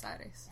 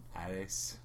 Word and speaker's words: Aires: How do you say it? – Buenos Aires. – Aires. – A-I makes I sound. – Aires.